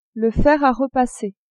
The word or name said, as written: fer a repasser